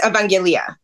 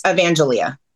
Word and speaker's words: Evangelia: In Greek, it's Evangelia. In English, it's Evangelia.